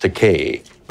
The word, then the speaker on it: Takei: When I walked into Gene Roddenberry's office, first of all, he mispronounced my surname. He called me Takei, but I corrected that. I said, my name is pronounced Takei, but I don't object to Kai because that's a Japanese word that means expensive.